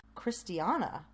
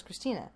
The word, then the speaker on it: Christiana: Oh, no, I think I was saying this wrong in my mind the whole time. Christiana. I thought it was Christina.